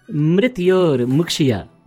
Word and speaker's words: mrityor mukshya: Mitaror mushki. – Mitaror mushki. No, it is mrityor mukshya. Mrityor. Yes. Mrityor.